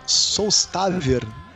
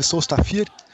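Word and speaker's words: Sólstafir: Uma banda que chama Solstafir. Na verdade, se escreve Solstafir.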